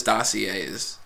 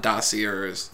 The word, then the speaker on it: dossiers: That is how you say it, right? It is Dossiers. It's not Dossiers.